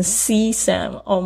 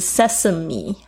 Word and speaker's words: sesame: 看我有多无知，那个时候差不多是快要二十年前的时候，讲成 C Sam，Oh my God！后来呢，那老师非常好，他就是在就是听完我口试之后呢，还给我就是发音的反馈，所以这也是为什么我觉得发音非常重要，在我们的这个，所以大家不要忘记是 Sesame 哦，它是 S E S A M E Sesame Paste。